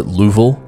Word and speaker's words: Louisville: And everybody from Louisville is mad right now because they pronounce it Louisville. But that sounds stupid to me, so I'm going to say Louisville.